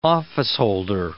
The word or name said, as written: officeholder